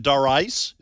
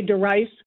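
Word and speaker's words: Darice: Do what most people pronounce it, Doris? Is that what you're saying? – Exactly. My music minister used to play the piano in church, and he'd say, okay, DeRice, let's do this.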